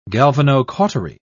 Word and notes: galvanocautery: British English

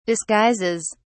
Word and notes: disguises: British English

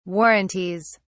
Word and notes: warranties: British English

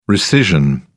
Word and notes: rescission: British English

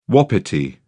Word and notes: wapiti: British English

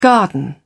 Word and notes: garden: British English

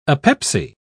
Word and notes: apepsy: British English

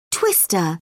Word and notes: twister: British English

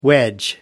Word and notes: wedge: American English

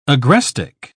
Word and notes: agrestic: British English

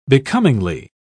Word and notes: becomingly: British English

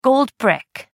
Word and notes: gold brick: American English

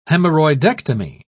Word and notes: hemorrhoidectomy: British English

